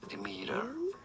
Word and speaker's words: Demeter: In British should he say it as? Demeter. – Yeah. – Demeter. Three syllables. – Wait. – Second syllable. Demeter in American English in the US as opposed to the UK. It's usually said as Demeter.